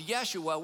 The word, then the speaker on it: Yeshua: It's where we get the name Joshua, but they didn't have a J, so it's Yeshua.